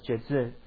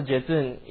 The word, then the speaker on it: Jetsun: So the word Venerable in Tibetan is pronounced as Jezun. So Jezun, you can spell as J-E-T-S-U-N, Jezun.